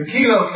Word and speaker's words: Kelo: I call it Kelo, it may be pronounced Kelo, versus Township of North New Haven. The Kelo case, K-E-L-O case, involving lighted areas.